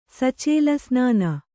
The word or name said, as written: ಸಚೇಲಸ್ನಾನ